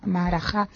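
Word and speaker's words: Maharajá: No se puede dejar en esta ocasión Argenoa, o en el mismo texto se habla del estilo de Maharaja, o Maharaja, ¿cómo se dice en castellano? – Maharaja, que es en alemán Maharaja-Stil, es decir, que hay que adaptar la grafía al castellano, a la dicción castellana en estas ocasiones.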